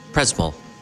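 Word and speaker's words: Przemyśl: And could you pronounce exactly where we? It's Przemysl. Przemysl. This town where we are. – This Przemysl. – Przemysl. I'm sorry. Przemysl. I pronounce it completely different.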